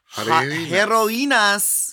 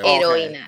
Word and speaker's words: heroínas: Yeah, the H is silent in Spanish always. – Okay. Heroínas. Heroínas de España. – Is that a rule? That's the rule? – H is silent. – If the word starts with an H, it's silent. Yeah.